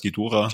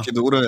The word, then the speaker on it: Ghidorah: So spricht man aus, Gedora. Gedora, ja.